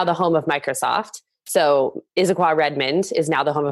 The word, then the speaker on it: Issaquah: Issaquah. Issaquah. It's a mouthful. – Issaquah.